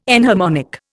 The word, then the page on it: enharmonic: Indian